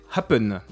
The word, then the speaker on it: Happn: La deuxième appli qui marche beaucoup en ce moment, c'est Happn, et c'est le concurrent direct, donc ça s'écrit H-A-P-P-N. – D'accord, voilà. Un peu comme un happening ou tout. – Ouais, happen. Voilà, mais en raccourci, quoi.